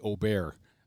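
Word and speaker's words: Åberg: You know, Aberg was in that Aubert was in that playoff. Is essentially from the Ryder Cup when he said, no, my name is actually pronounced Aubert, not Aberg, Aberg, what have you. It's Aubert.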